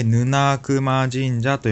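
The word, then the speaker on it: 沼名前神社: 沼熊神社っていうのは、まあ、漢字が難しいんですけど、読み方が難しいですね。えー、沼、泥沼の沼に名前、と書いて、ヌナークマ神社と読みます。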